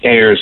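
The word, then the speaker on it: aires: Yeah, well, it's the French name Ayres.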